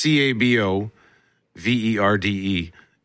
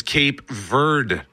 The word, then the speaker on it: Cabo Verde: The official spelling is Cabo Verde, and they had the country actually, I think, in 2013, sort of formally announced they would like to be known as Cabo Verde. That's, I believe, the Portuguese pronunciation, but it's also known as Cape Verde, or I've also heard Cape Verde.